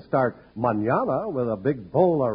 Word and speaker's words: mañana: It's the word mañana, spelled M-A-N-A-N-A, and pronounced mañana.